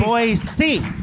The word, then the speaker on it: Boise: Hello, Boise. – We're so excited. Boise. – It's Boise. – With the hard S.